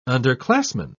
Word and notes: underclassman: British English

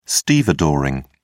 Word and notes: stevedoring: British English

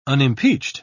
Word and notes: unimpeached: British English

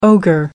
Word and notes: ogre: American English

